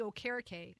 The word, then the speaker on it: Okereke: Last question for you in regard to the defense, and that is Bobby Okereke. Am I pronouncing that properly? I just go with Bobby O. – Yes. – I'm horrible. – No, you got it right.